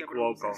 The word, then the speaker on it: Cuoco: Y Kaylee Cuoco, si es que se pronuncia. – sí, Cuoco.